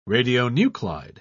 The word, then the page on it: radionuclide: British English